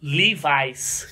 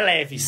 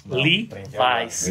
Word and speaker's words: Levi's: Puta que pariu, 100% algodão, México Levi's, que é como você pronuncia, não é Leves, você sabe, né? – Levi's.